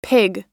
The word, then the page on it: pig: noun